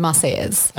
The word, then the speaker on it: Marseillaise: The Marseillaise.